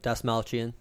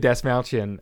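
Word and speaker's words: Dastmalchian: And then you get our boy, David Malstastian. – Dasmalchian. – David Dasmalchian as Peter DeVries.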